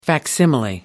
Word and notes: facsimile: American English

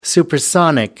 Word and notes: supersonic: American English

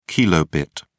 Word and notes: kb: British English

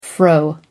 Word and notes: fro: American English

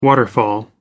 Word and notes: waterfall: American English

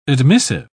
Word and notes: admissive: British English

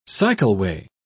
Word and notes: cycleway: British English

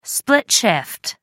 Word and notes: split shift: American English

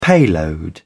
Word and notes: payload: British English

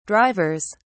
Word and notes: drivers: British English

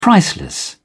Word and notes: priceless: British English

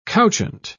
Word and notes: couchant: British English

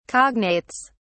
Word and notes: cognates: British English

